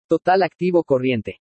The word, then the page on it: TOTAL ACTIVO CORRIENTE: spanish